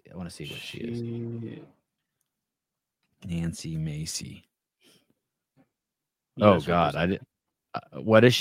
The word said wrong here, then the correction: Mace: Do I have her name wrong? – Nancy Mace. – Oh, Nancy Mace.